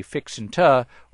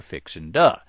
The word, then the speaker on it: fixin' to: Use guys or you-uns for you Yankees, and the proper pronunciation of fixin' to is actually fixin' ta or fixin' duh.